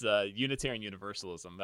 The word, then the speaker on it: Unitarian Universalism: I keep calling it universalitarianism, and I know that's not right. – Well, it's close. It's Unitarian Universalism.